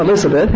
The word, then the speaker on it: Elizabeth: My real name, Elizabeth, has four syllables, doesn't it? But the Southerners call me Lizbeth. Two syllables.